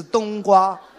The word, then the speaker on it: 冬瓜: 不是东光老师，是冬瓜，别叫错，注意发音。